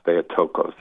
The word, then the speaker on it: Theotokos: It just takes the dogma of Mary, the Mother of God, the Theotokos, Theotokos as it's pronounced today, the Mother of God.